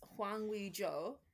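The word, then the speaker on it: Hwang Ui-jo: How do you pronounce that, huh? – I would say it's Hwang Wee-jo, but okay. Yes.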